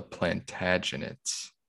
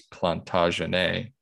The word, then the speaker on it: Plantagenet: Yes, because I looked it up on YouTube and they said the French pronunciation is Plantagenet and the English is Plantagenet.